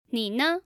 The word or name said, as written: ni ne